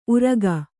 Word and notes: ಉರಗ: Kannada

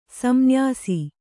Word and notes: ಸಂನ್ಯಾಸ: kannada

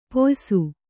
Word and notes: ಪಯಸ್ಸು: Kannada

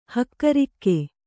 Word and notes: ಹಕ್ಕಿರೋಗ: hakki rōga

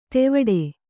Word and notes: ತೆವಡೆ: tevaḍe

